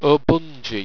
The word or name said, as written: obungi